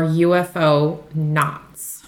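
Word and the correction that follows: ufonaut: I can't believe you've seen all 10 episodes of Hellier and you did not pronounce it euphonaut.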